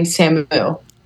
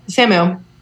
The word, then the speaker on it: Samu: So it became Samu because they have a Sa and a Mu. you know, so Samu.